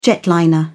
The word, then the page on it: jetliner: British English